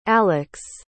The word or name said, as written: alex